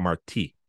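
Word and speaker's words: Martí: There's an accent on the I, a Marti.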